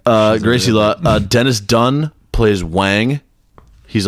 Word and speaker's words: Gracie Law: We have Kim Cattrall as Gracie Law. Did I say that right? – Yes, Gracie Law.